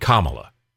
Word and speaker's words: Kamala: Joe and Kamala. Remember, pronounce her name correctly. It's Kamala, not Kamala, not Kamala. It's Kamala, like the punctuation.